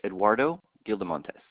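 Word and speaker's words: Eduardo Gildemontes: It's Eduardo Gildamontes. – Spell that for me. – Eduardo is E-D-U-A-R-D-O, and Gildamontes is G-I-L-D-E-M-O-N-T-E-S.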